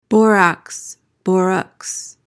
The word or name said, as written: borax